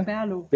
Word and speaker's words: Birlo: ähm, jetzt, ähm, also also man kann es aussprechen, wie man will. Stimmt, ich ne? spreche es auch anders aus als du. – Ich ah, ja? Berlo. Berlo, okay. genau.